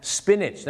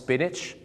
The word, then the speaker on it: spinach: spinach ไม่ใช่สปีแนทหรือสปีนาทนะฮะ spinach นั่นเองครับออกเสียงว่า spinach